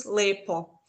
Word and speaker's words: lepo: Äh, ich sagt er immer so ein Beispiel, äh, das Wort schön heißt bei uns auf Kroatisch Lijepo und auf Serbisch heißt es Lepo.